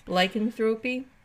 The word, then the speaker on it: lycanthropy: And I don't know if I'm pronouncing that word right, lycanthropy? – Lycanthropy? Well, you said lycan right. You said I think it's lycanthropy. Let's go with that.